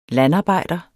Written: landarbejder

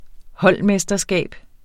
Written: holdmesterskab

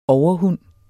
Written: overhund

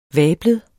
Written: vablet

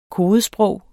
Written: kodesprog

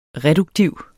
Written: reduktiv

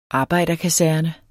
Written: arbejderkaserne

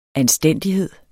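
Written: anstændighed

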